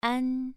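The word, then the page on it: an: tone 2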